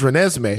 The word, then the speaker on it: Renesmee: I'm sorry for pronouncing her name wrong, by the way, guys. Her name is Renesmee.